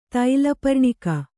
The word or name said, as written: ತೈಲಪರ್ಣಿಕ